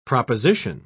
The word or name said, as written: proposition